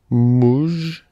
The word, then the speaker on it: муж: Муж.